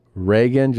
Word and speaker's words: Reagan: You pronounce Reagan just like Ronald Reagan. This is also the way you pronounce Megan. Just replace the R with an M.